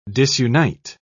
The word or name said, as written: disunite